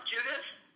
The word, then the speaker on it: Judith: Judith, J-U-D-I-T-H,